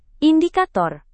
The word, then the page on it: indikator: indonesian